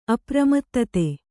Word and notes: ಅಪ್ರಮತ್ತತೆ: apramattate

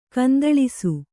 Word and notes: ಗೊಂದಲಿಸು: Kannada

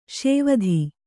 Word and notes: ಸಾವಧ: sāvadha

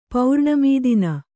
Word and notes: ಪೌರ್ಣಮೀದಿನ: Kannada

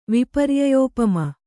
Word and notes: ವಿಪರ್ಯಯೋಪಮ: kannada

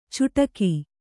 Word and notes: ಚುಟಕಿ: cuṭaki

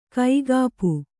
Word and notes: ಕೈಗೊಪ್ಪೆ: kaigoppe

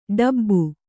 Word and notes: ಡೆಬ್ಬೆ: kannada

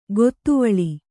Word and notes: ಗೊತ್ತುವಳಿ: gottuvaḷi

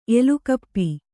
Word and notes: ಎಲುಕಪ್ಪಿ: elukappi

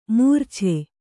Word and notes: ಮರಿಚ: marica

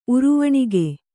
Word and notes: ಉರುವಣಿಗೆ: uruvaṇige

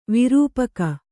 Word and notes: ವಿರೂಪಕ: kannada

